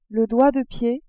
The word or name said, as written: doigt de pied